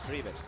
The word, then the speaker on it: Reuvers: ¿Cómo se pronuncia Reuvers? ¿Reuvers? ¿Reuvers? ¿Rivers? – Rivers. Hay que ir a la fuente. Es que como río en plural. – En ACB, Rivers, sí, Rivers.